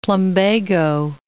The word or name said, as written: plumbago